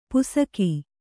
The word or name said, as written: ಪುಸಕಿ